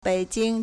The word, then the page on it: 北京: běijīng